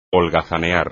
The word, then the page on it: holgazanear: espagnol